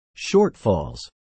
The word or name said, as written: shortfalls